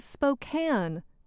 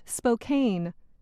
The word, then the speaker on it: Spokane: The correct pronunciation is Spokane, not Spokane.